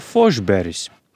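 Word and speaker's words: Forsberg: Sandu Ozoliņa bijušais komandas biedrs Stenlija Kausa ieguvējis Peters Forsbergs vai Fošberis.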